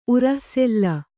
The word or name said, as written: ಉರಃಸೆಲ್ಲ